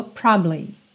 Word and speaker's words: probably: Essa palavra é muito interessante, porque probably quase sempre soa probably, probably, probably. É como se você passasse tão rápido, mas tão rápido por essa sílaba do meio que ninguém nem escuta.